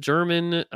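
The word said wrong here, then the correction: Germán: It's Herman, and it's Tyone, Jameson Tyone, like a tie that you own.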